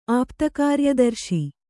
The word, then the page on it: ಆಪ್ತಕಾರ್ಯದರ್ಶಿ: āpta kāryadarśi